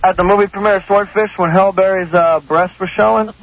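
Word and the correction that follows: Halle Berry: How come you say Hail Berry? – Well, how do you say it? – Hallie, I'm sorry.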